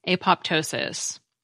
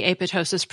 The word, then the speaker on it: apoptosis: Because if I'm reading this right, when the Greek PT combination appears in the middle of a word, the P usually is pronounced. The OED doesn't include the apoptosis pronunciation in the pronunciation section, but Merriam-Webster does include both pronunciations.